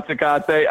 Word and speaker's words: Tecate: Am I saying that right? – Yeah. It's still presented by Tecate.